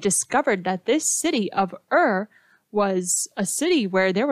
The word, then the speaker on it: Ur: It's only spelled with two letters, U-R, Ur, and it was near a river called Euphrates.